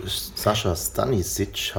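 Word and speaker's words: Sascha Stanišić: Zu Sascha Stanisic, habe ich es richtig ausgesprochen? – Ja, ich glaube schon.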